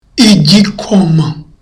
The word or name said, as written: igikoma